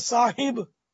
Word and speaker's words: صاحب: حضرت صاحب, اصل تو تلفظ صاحب ہے, صاحب صاحب نہیں صاحب, تو حضرت صاحب کہہ کر بھی تو وہ تعظیم بڑھا دی نا اس کی.